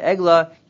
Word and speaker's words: Egla: So the pasuk says, the pasuk is found in Yirmiyah, Egla, that's right, pronounce it the proper way, Egla, Yefei Fiha, Mitzrayim, I have here Yefei Fiha, Mitzrayim, Keretz Mitzafon Bava.